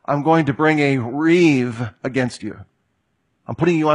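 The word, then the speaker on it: riv (ריב): It's the Hebrew word riv. It's spelled rib, but pronounced riv.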